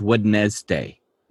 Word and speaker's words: Wednesday: What were they thinking putting the D in Wednesday? Nobody says Wednesday. Nobody does that. Basically, the English language is a little bit of a challenge to people. Not naming names, but rhymes with a wrist. Well, Wednesday... I just find that to be odd. Like, okay, so the D is silent.